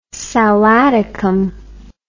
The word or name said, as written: sal Atticum